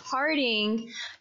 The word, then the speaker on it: Harding: I mean, I know that Hardigan, wait, why can I not get her name right? I'm combining names. You're trying to combine them. – I'm combining names. Harding, she like wore different outfits and stuff whenever you practice.